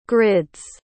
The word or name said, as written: grids